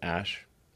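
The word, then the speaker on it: Æ: I mean, it's just X, the letter X, and then the AE is like pronounced Ash. yeah.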